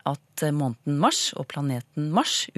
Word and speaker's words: Mars: Måneden mars med kort A og planeten mars med lang A, altså Mars.